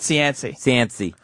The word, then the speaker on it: Cianci: Oliver Platt is rumored to be playing Mayor Cianci. Is that how you say it? – Cianci. Buddy Cianci. – I like Cianci. – Okay. – Because it's more like Chauncey then.